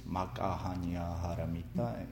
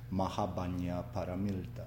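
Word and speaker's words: makahanya haramita (摩訶般若波羅蜜多): In Japan, it's pronounced in Japanese, makahanya haramita. In Korea, is pronounced in with Korean twist, mahabanya paramilta.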